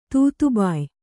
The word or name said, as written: ತೂತುಬಾಯಿ